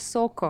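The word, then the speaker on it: Zuko: В английском, кстати, его имя так произносится, как Сака. Сака. – Нет, ты путаешь Соко. – О, Соко, я да, перепутал, да, да, да извините.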